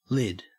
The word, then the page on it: lid: Australian English